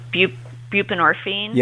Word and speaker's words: buprenorphine: He prescribed, I can never say, buprenorphine. – Yep. – That's how you pronounce yep. – it? Buprenorphine. Mm-hmm.